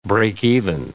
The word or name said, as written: breakeven